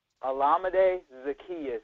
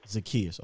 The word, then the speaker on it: Olamide Zaccheaus: Can you pronounce his name? Because earlier in my film study, and I talked about it here, I said there's a cat named Olamide Z or whatever – who was routing up the Saints. He had two touchdowns last week against the Saints. How do you say his name? – Olamide Zaccheaus. – Zaccheaus, okay.